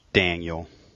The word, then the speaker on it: Daniel: Daniel.